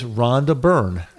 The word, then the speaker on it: Rhonda Byrne: That is Rhonda Byrne. – Okay, so she... – She's the author, Rhonda Byrne.